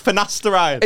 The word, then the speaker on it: finasteride: Get on Fendestride. – You're saying it wrong as well. It's Finasteride. – Is it not Fendestride? – No.